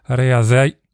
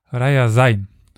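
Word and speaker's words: Rjasan: Das ist ein Zweig der Mordwinen oder also auch Moksha genannt und war beim Fall von, dieses Wort habe ich dem letzten end, oder diesen Stadtnamen endlich richtig aussprechen gelernt. Es ist die äh, ja, südrussische Stadt Rajasai. Yes, es ist schwierig. Rajasai.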